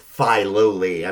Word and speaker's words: Filoli: Or, I'm sorry, it's pronounced Filoli. I'm sorry, Filoli.